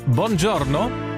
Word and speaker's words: Buongiorno: Guten Morgen und Buongiorno. Habe ich das gut ausgesprochen? Hanna spricht ja perfekt Italienisch. – Perfekt hast du es ausgesprochen.